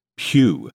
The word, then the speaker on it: Pugh: so let me prove I finally figured out how to say this. Her name is Florence Pugh, not Elizabeth Plug, which, you know, I apologize.